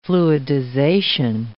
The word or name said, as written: fluidization